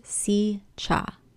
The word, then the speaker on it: Sica: One of the more popular campsites is in the Secha Hollow State Park, and it is spelled S-I-C-A, but pronounced Secha.